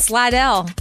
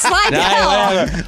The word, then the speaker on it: Slidell: I played softball tournaments in Slidell. – Slidell? – No, it's Slidell.